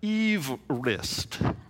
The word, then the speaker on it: Everest: Well, he didn't pronounce it Everest. He pronounced it Everest. Everest. But nobody got the memo.